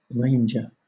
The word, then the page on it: ranger: British English